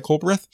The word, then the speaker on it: Culbreth: And then the, guy I'm really kind of low key expecting to turn to like a Frank Duncan type is uh, Ty Colbert or Ty Culbreth, I believe it's pronounced Ty Culbreth, uh, lefty He's a starter.